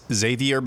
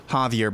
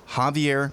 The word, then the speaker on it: Javier: Google tells me it's Xavier Becerra. He pronounces it Javier Becerra. It's a really weird way to say Javier. It is Javier Becerra, HHS Secretary Javier Becerra.